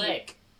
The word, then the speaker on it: clique: Click? I always want to pronounce it clique. – Click. Click. – But why is Don't embarrass me. – Why is the E there? It's a French word. – I don't know. Clique. – If it's French, then it should be clique.